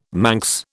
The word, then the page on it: Manx: British English